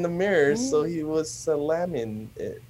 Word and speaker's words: Salamin: Salamin, you spell it as S-A-L-A-M-I-N. – Mm-hmm. – So the word that's very close to salamin is salami.